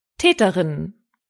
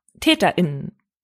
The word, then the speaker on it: TäterInnen: Hört nochmal genau hin und achtet auf die Betonung. Weiblich, Täterinnen. Alle, TäterInnen. TäterInnen, TäterInnen.